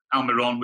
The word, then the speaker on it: Almirón: You know, and Atlanta United need to be applauded because they did a tremendous job by bringing in Martinez, Joseph Martinez, Almirón, which, by the way, thank you for saying his name correctly.